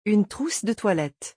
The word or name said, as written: une trousse de toilette